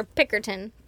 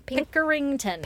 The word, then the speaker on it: Pickerington: Pickerington or Pickerton? – Pickerington. Pickerington. What I wrote, so, you know. – I love it. – Maybe all you right. don't pronounce the ing, Pickerton. – Pinkerton. – Be dumb just to cut know. Out the whole ing. – No, I mean, Pickerington. If it's on there, it's on there.